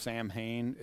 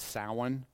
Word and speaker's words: Samhain: So if you look at this one, which looks like it's pronounced Samhain, it's Samhain. Okay, that happens on November 1st.